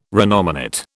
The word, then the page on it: renominate: British English